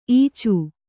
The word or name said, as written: ಈಚೆ